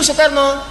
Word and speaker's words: Soekarno: Orang lama u-nya ditulis pakai oe. Ya. Insinyur Soekarno, diantara proklamator bangsa kita, Indonesia.